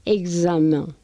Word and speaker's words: examen: y en otros casos la X también lo pronunciamos como GZ. Exemple, examen. Eso